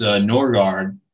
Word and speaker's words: Norgaard: Okay, well, you know, I was always told it's Norgaard. My dad was from a Minnesota where there's a lot of Scandinavian diaspora.